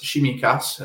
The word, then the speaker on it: Tsimikas: That said, Kostas Tsimikas, another Greek who starts with a TS, if that helps with the pronunciation.